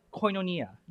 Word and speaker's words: koinonia: So, and this word right here is pronounced, next slide, koinonia.